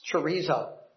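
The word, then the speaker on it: chorizo (χωρίζω): It's chorizo. How many of you like chorizo sausage? I do. But that's not what this word means. It's just pronounced that way in the Greek language.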